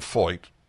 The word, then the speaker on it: Feucht: Anyway, a man named Sean Foyt, and it's not spelled like it's pronounced, I'll tell you now, F-E-U-C-H-T, is the star of Super Spreader.